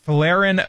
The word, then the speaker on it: Folarin: Folarin Balogun, thank you, clearing him to play after President Donald Trump personally urged FIFA President Gianni Infantino to review the case.